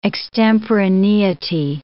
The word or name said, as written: extemporaneity